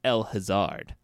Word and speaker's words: El Hazard: Yeah, it is spelled like El Hazard. It's pronounced El Hazard.